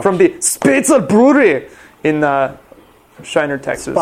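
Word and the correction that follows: Spoetzl: Spatzel. Which, say it like you're in Texas. – From the Spatzel Brewery in Shiner, Texas.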